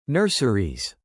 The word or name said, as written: nurseries